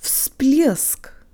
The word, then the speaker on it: всплеск: Всплеск. If you thought the V sounded like an F, you were right.